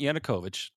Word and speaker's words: Yanukovych: President Yanukovych.